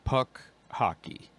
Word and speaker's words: Puck Hockey: Have you heard of this company called, I think I'm reading it right, Hawk Pucky? – No, but I like the name. – Yeah, I'm sorry. I said it wrong. Puck Hockey. It's spelled P-U-C-K, second word, H-C-K-Y.